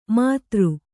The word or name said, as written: ಮದಿರೆ